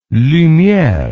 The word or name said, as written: lumiere